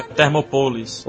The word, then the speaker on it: Thermopolis: Não, gente, a pronúncia certa, se você não sabe falar grego, é Thermopolis.